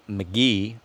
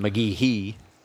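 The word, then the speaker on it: McGehee: We're in McGee, Arkansas, as I was instructed. I've been saying it wrong for a bit. I guess since we got here. – Yeah, since the drive down, we've seen, you know, every time we look at the map, we're saying it wrong. – Mm-hmm. McGee-hee. That's not correct.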